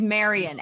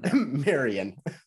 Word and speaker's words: Marian: Yeah, it's Marion, M-A-R-I-A-N. So Marion. – I don't know. Okay. That's how you pronounce it. That's how you actually pronounce it.